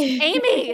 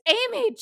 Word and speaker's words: Amy: You know, they were like, Ami, Ami. And I'm like, no, it's just Amy. It's Amy.